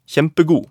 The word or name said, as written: kjempegod